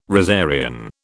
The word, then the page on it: rosarian: British English